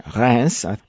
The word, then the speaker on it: Reims: Fiona, your research took you to, now I'll try the French pronunciation, Reims.